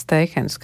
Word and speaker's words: Steihens: Fotogrāfs Edvards Steihens, kā ir vienojušies latviešu valodnieki, tā izrunāt viņa uzvārdu.